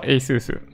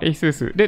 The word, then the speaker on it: ASUS: だけど、読み方はエイスース。なるほど。昔は営業さんにもいろんな読み方をしていたんですけど、いつの間にかエイスースで統一。